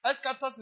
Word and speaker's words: eschatos: So, now, eschatos is spelled E-S-C-H-A instead of K. E-S-C-H-A. Esca. E-S-C-H-A-T-O-S. So, eschatos.